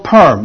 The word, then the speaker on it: Perm: One is called Perm, like a woman's permanent Perm.